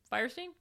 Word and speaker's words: Fierstein: So in 1999, this is a film made by Harvey Fierstein. Am I saying that right? – Firestein? – Thank you. Harvey Firestein.